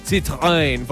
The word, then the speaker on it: Citroën: Vamos a tener por allá Honda, vamos a Zanautos, Citroën con Alcalá Motor, perdón, lo dije mal. Citroën, porque es una marca francesa.